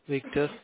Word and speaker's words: Victor: Victor. – Can you spell that? – V-I-C-T-O-R, Victor.